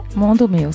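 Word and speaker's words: Mundo Meu: On vous retrouve avec un deuxième album, alors que j'espère prononcer correctement, Mundo Meo. – Mundo Meo, c'est ça.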